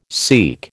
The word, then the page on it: seek: American English